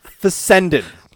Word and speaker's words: Fessenden: Doesn't sound right. Hold on, say it again. – It's F-E-S-S. – Larry Fessectomy. – Here, let me spell it. It's F-E-S-S-E-N-D-E-E-N. – Fessenden. – Fessenden. – Fessenden. – Larry Fessenden. – Harry's trying to pronounce every single letter in the guy's name. – Pronunciation is my thing. Like, I can't skip a letter. I can't blur them.